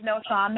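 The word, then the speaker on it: Knowshon: And yes, his name is No'Shawn.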